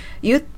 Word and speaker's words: You'd: レッスン 3 週目の 3日目は、まずウッドの短縮形のアポストロフィー D もカナダ英語、アメリカ英語では発音されずにためを作るだけというルールにのっとって発音されないというのを学習していきました。 You'd prefer でしたね。